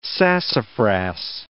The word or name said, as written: sassafras